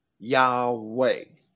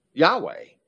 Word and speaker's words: Yahweh: There were no silent vowels in Latin. That, that, that those vowels were pronounced or they became a diphthong. I O U E Yahweh, Yahweh. The I, when it be, begins a when it begins a syllable and it's followed by a vowel, is pronounced like we pronounce a Y.